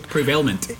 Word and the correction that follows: prevalent: No, prevalent.